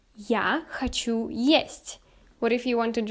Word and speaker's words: есть: In Russian, it's gonna be есть.